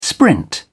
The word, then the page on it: sprint: British English